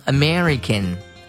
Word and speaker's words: American: I C A N American American，注意这个词的发音，它发成 American，而不是 America。